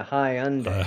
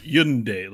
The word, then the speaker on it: Hyundai: Maybe it's more like Hyundai. Like Yun. Like the Y's got to be there for a reason, right? – Well, here's what they say.